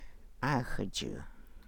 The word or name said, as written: Gaa xudjuu